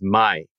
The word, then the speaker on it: mai: Kome is the, K-O-M-E is the most common way to say rice in Japanese. But when you team it up with another word or another character, then it often is pronounced as mai.